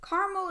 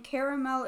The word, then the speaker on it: caramel: So caramel versus caramel. Caramel is the correct way to pronounce it. We have done some heavy research, and we have concluded that our assumption is correct, and caramel is a terrible way to pronounce it.